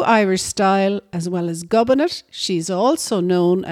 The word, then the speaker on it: Gobnait: Gobinet is spelt G-O-B-N-A-I-T, and Gobinet is an ancient Irish name meaning bringer of joy.